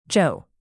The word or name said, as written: joe